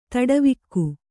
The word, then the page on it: ತಡವಿಕ್ಕು: kannada